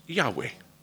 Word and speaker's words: Yahweh: It's Y-H-W-H. And it's drawn from the meaning of God's name, I am that I am, and it's pronounced Yahweh.